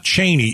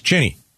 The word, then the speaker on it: Cheney: It's now it's not Cheney, it's Cheney.